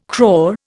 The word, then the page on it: crore: Indian